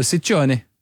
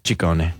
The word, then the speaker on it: Ciccone: Well, in Italian, it works the other way, so the double C's are hard C's, and the singular C's are soft C's. – So Ciccione. – Ciccone.